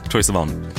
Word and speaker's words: Troye Sivan: Uttalar jag korrekt, eller? – Ja, Troye Sivan.